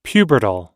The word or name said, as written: pubertal